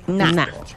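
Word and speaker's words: na': Na.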